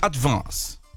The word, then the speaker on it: advance: É um A puxando pro E, ó. Que vê a diferença, tá. Ó. Advance. Aí você falou advance. Mas, ó, é um E puxando. Ó. É advance.